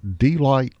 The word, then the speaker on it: Delight: He grew up in, and this is the pronunciation, Delight, Arkansas. The delight of Delight, Arkansas.